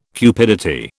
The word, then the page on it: cupidity: British English